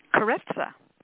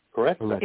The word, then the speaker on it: Carezza: It covers what tantra and sex magic and, forgive me if I pronounce it wrong, Carazza? – Carezza. – Carezza. – It's Italian.